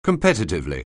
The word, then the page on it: competitively: anglais